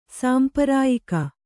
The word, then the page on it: ಸಾಂಪರಾಯಿಕ: sāmparāyika